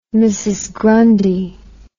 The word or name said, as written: Mrs Grundy